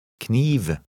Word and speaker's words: kniv: Read number 18, pronouncing the first 2 letters separately. – Kniv.